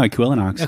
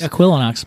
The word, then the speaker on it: Equilinox: It is almost perfectly phonetic. – Yeah, I was going to actually, I tried to pronounce it a few times in my head, and I decided I wasn't going to do it out loud. – Well, there you have it now, Equilinox.